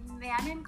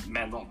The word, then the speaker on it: Manon: Do you pronounce her name Manon Garcia, or is it Mano Garcia? – Yeah, Manon. – Okay, right, yeah, the French accent.